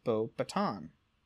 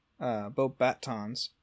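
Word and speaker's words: Beauxbatons: In the UK and US audiobook editions of Harry Potter and the Goblet of Fire, the pronunciation of Bobaton sounds more French, as both Stephen Fry and Jim Dale respectively not having pronounced the S at the end of the name. Pottermore confirms Fry's pronunciation styling as Bobaton. – Mm-hmm. – In the film adaptation of Harry Potter and the Goblet of Fire, Bobaton is incorrectly pronounced by Dumbledore as Bobaton. Bobatons. This appears to be an error in the pronunciation on Dumbledore's behalf, seeing as the French students pronounce it correctly.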